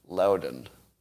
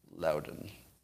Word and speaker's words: Loudoun: Uh, I'm being I'm being told that the pronunciation of that school is uh, Loudoun. Loudoun.